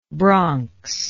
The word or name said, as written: bronxite